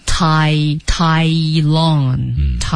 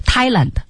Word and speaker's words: Tai Lung: Tai Lung. Thailand. Thailand. 你不能读成 Thailand.